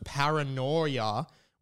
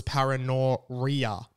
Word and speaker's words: paranoia: Of course, the other ongoing one is my pronunciation of paranoia, which I always thought was paranoria, paranoia, paranoria is what I thought.